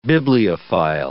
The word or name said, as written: bibliophile